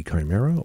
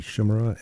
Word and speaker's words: Chimera: And Chimera, or Chimera, depending upon whether you like your pronunciation of this mythical creature, comprised of three different animals, at least, as you like your pronunciation. Greek, which would be Chimera, or Latin would be Chimera.